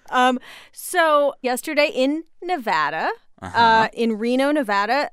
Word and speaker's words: Nevada: I said when I came out here, I said, nobody says it the other way. It has to be Nevada.